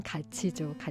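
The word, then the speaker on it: 같이: 함께라는 것도 발음으로는 가치죠.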